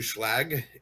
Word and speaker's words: Schlag: The last name is spelled in both places, S-C-H-L-A-G. If you renovate a lot of houses, you know the lock, the Schlag locks.